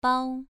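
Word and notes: bao: tone 2

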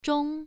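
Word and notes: zhong: tone 1

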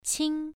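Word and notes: qing: tone 1

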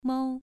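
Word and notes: mou: tone 2

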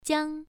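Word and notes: jiang: tone 1

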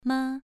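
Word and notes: ma: tone 2

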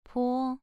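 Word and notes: po: tone 2

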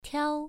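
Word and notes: tiao: tone 1

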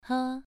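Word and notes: he: tone 2

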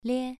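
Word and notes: lie: tone 2

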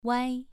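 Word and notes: wai: tone 2

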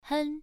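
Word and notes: hen: tone 1

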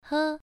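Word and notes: he: tone 1

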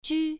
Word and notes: ju: 1

